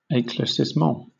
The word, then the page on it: eclaircissement: British English